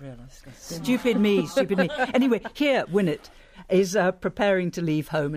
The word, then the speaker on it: Winnet: This is a bit concerning a heroic young woman called Winnet. Is that how you pronounce that? – Yeah, I think it is, because in the book she's called Winnet E.